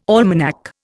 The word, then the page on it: almanac: Indian